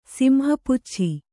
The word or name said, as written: ಸಿಂಹಪುಚ್ಛಿ